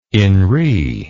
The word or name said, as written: in re